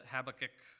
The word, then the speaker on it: Habakkuk: With that said, if you would, please turn with me to Habakkuk, or as the English pronounce it, Habakkuk.